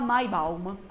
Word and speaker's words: Maibaum: Lì si chiama Maibun, Maibaum. Ah già, Baum. Si chiama Maibaum.